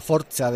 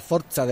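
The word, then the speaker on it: forza: Bueno, yo creo que está más cerca de la forcha del destino que de la fuerza del destino, pero en cualquier caso, la forcha del destino, 4 funciones, 25, 28, 31 de octubre y 3 de noviembre, como decíamos, en Euskalduna, Bilbao.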